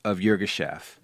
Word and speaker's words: Yirgacheffe: Yirgacheffe.